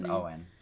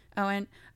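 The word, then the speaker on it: Eion: It's like E-I-O-N. I don't know how to pronounce that. – That's Owen. – Owen.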